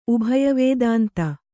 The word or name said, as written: ಉಭಯವೇದಾಂತ